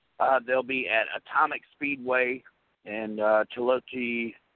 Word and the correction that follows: Chillicothe: Ohio. Miss Lee, did I say that right? Chillicothe, Chillicothe, Ohio, at Atomic Speedway uh, for $12,000 to win.